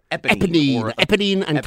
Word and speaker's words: Eponine: And Epiphany, how do you say? I always screw this name up, even when I see the show. – Help me. – Eponine, Cassette.